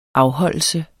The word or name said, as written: afholdelse